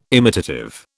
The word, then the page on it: imitative: British English